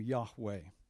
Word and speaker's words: Yahweh: Lord is the name Yahweh. That's probably the correct pronunciation. Most modern scholars say very likely there's a stop at the H and you start a second syllable.